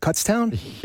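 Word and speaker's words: Kutztown: Is that Cutstown? – Yes, it is.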